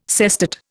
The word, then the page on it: sestet: Indian